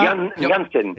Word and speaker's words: Janssen: Alors c'est vrai qu'on trouve euh, Yann, est-ce que je prononce bien d'ailleurs son nom Yann Janssen, c'est bien ça Yann Janssen. – Janssen, ah c'est pas Janssen comme Jean-Fille, vous voyez.